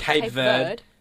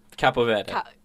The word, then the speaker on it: Cabo Verde: Is it not Cape Verde? Is that not how you say it? Well, just, like, I'm not going to... – Cabo Verde. – Oh, okay. Thank you very much for that Spanish.